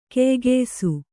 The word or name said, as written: ಕೆಯ್ಗೆಯ್ಸು